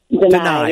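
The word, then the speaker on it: Danai: And so, first of all, it was written by, as I said, Danae Gurira. I hope I'm saying her name right. Deny. Yeah. – Okay. I said it, I wasn't sure I was getting it right. So deny. Yeah.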